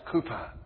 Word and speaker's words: Cowper: You spell his name C-O-W-P-E-R. But he's pronounced Cooper.